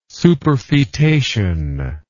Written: superfetation

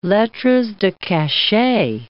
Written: lettres de cachet

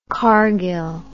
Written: Cargill

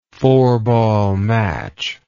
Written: four-ball match

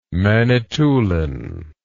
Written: manitoulin

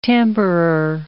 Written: tamperer

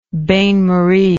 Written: bain marie

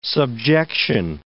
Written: subjection

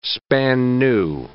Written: span-new

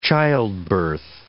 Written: childbirth

